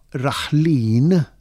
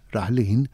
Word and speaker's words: raħlin: Jekk nieħu l-kelma raħlin, ara, qed inħoss vokali twila fit-tieni sillaba in raħlin, hija twila dik alla dik tieħu l-aċċent ewlieni.